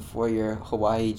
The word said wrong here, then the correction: Huawei: Jack, or Huawei. Huawei is the right way to pronounce it, your Huawei Jack.